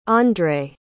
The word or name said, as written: Andrei